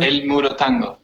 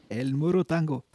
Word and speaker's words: El Moro Tango: Hvordan uttales det? Eh, El Moro Tango. El Moro Tango.